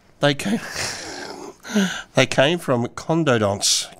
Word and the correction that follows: conodonts: Or the pronunciation. C-O-N-O-D-O-N-T-S.